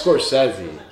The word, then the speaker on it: Scorsese: Scorchese. – Is it Scorchese? – No, it's Scorsese.